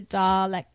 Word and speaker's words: Daleks: And so now, like, I really appreciate, like, the Daleks.